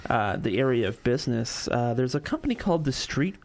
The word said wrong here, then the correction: Streit: I think it's Strite. Yeah, Streit. Street Strite. Strite Group.